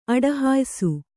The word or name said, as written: ಅಡಹಾಯಿಸು